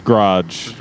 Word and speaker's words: garage: My mom says garage. G-R-O-D-G-E. – I have not thought about that song in a minute. I try not to think about Weezer. – References. That's fine. I don't think you think about it often. – It distinctly rhymes with the word dodge, the way that...